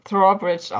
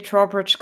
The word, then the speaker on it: Trowbridge: Und heute sind wir auch bei so einer Abendgesellschaft und zwar bei Lady Thambridge. Ja, ich würde es Thrawbridge aussprechen. – Thrawbridge? Ja. Okay, Thrawbridge. – Ich hätte es auch Lady Trawbridge gesagt. – Ja, können wir gerne Lady Trawbridge richtig aussprechen.